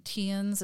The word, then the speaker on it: Tiaan: And so this is when Tian Elliard, and this is different than Tian's. It's spelled T-I-A-A-N. Oh. So it's just Tian, I believe.